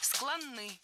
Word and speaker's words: склонны: Склонны. Неверно. Правильный вариант – склонны. Ударение в этом кратком прилагательном такое же, что и в полном. Склонны, а не склонны.